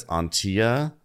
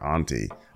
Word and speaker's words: Auntea: Yeah, her name is, I believe it's Auntia, but spelled like A-U-N-T-E-A, and it's really hard not to call her Auntie or Auntie.